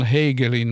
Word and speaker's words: Hagelin: kannattaa tutustua semmoisiin henkilöihin kuin John kirjoittaa tai lausutaan Hegelin, mutta hän, tuo on ruotsalaisperäinen nimi, eli John Hagelin.